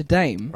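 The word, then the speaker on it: Daim: Because a dime originates from Sweden. – Okay. – Where it's obviously pronounced dime, dame. But is dame.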